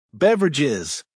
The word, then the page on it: beverages: American English